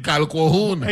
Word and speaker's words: Calquohoun: Q-U-O. – That's the middle syllable. Yes. – C-A-L-Q-U-O-H-O-U-N. – Kalkohun.